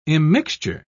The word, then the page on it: immixture: British English